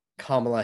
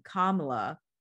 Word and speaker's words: Kamala: So we're not talking about Kamala Harris. – And pronunciation is important. So our VP pronounces her name Kamala.